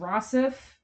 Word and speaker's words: Rossif: I believe it's pronounced Rossif Sutherland, Kiefer Sutherland's half-brother, we literally just found that out.